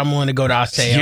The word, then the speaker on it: Osseo: And it's still pronounced Osseo.